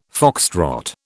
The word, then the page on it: foxtrot: British English